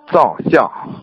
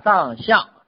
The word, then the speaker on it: 藏象: 藏相。 – 说的对啊，藏相。我们现在呢用的是这个藏，看见了吧？这个藏相，嗯，有什么区别呢？你看古代那个藏啊，很多人他不知道中医，他念成藏了，是不是像藏起来的藏啊？